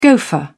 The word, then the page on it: gofer: British English